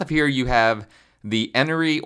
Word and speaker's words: Enoree: It's spelled E-N-O-R-E-E, so I personally don't see how you can pronounce it Honoree, but I have heard a lot of locals pronounce it that way.